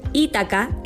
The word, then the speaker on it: Ítaca: Ítaca es una isla griega ubicada en el mar Jónico. Aunque en griego es una palabra llana, en español se pronuncia habitualmente como palabra esdrújula.